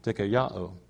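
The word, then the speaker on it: dikaiō (δικαιόω): Righteousness, or the Greek word dikaiō is how you say it. I know because I got on Google and I hit the little speaker thing and it said it for me. And so that's how they said it, dikaiō.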